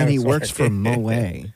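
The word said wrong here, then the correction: Moët: It's pronounced Moet, by the way. – No, Moe. – No, it's not. – You don't pronounce the T. – Yeah, you do.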